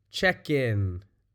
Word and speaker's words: check-in: But the way we say it, check in. We kind of move that K, that K, over to the beginning of the in. Kin. Kin. So it's kind of pronounced check-in. Check-in.